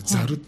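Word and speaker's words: 笊: 竹冠に爪って書いてザルって読むん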